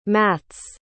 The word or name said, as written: Matz